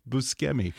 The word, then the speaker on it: Buscemi: I did that to Steve Buscemi just the other day as well, which apparently is supposed to be pronounced Buscemi. – Yeah, because the that that's it's different than in English. That that C. One C in yeah, Italian the is C's the it's a hard C, you, yeah.